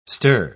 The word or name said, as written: stir